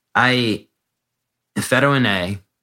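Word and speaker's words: Fetuin-A: Let's talk about Fetoin A, because I was reading your cardiac manifesto, to pronounce it in somewhat of a French way. But Fetoin A,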